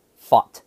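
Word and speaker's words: Pfaadt: So I had to look it up. It's pronounced Fought.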